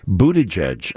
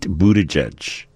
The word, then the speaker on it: Buttigieg: A couple of many different pronunciations coming for Mayor Pete in South Bend. – The good old Mayor Pete in South Bend, as I will call him from now on. Buttigieg, apparently. I guess that seems to be the most common.